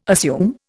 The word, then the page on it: assume: Indian